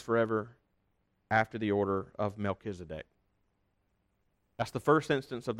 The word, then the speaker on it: Melchizedek: That is how you say his name, by the way, though. Melchizedek.